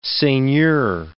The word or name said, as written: seigneur